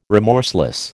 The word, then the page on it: remorseless: American English